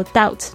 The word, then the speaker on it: doubt: Confidence when you doubt、这个 confidence 就是这个自信的名词，当你就是怀疑的时候 doubt，注意一下 D-O-U-B-T，这个 B 不发音。